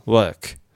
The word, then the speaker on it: work: 本当に口を開けずにですね、うーとか、うー、うーみたいな音を言うと、これでイギリスっぽい音になります。 hurt, hurt. 働くだと work。で、アメリカ発音とかだと、舌を丸めるんですね。うー、 er て言います。それで hurt, work で